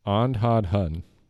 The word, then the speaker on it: Andhadhun: Andhad Hun. – Okay, I'm going to say Ohnhadhun. – You got the last three letters correct. – Oh, so close. – It's much simpler than that. A-N-D-H-A-D-H-U-N. – A-N-D.